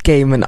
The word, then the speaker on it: Gaiman: Der wird aber echt Gaiman ausgesprochen, weil ich habe die tolle DVD-Sternwanderer, die hoffentlich morgen wieder von Vivian zurückkommt. Ich hoffe es. Ja. Und da haben die gesagt, dass der Neil Gaiman heißt.